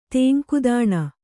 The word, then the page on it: ತಂಗುದಾಣ: kannada